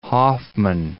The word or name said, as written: Hoffman